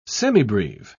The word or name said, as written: semibreve